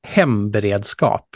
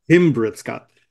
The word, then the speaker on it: hemberedskap: We're recording this and it'll come out again in a couple of weeks, but I think it's Humberskap. Is that right? Or did I say it wrong? Correct. Yeah. Say it correctly. How about that? – Hemberedskap. – Hemberedskap.